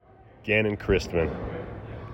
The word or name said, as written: Gannon Christman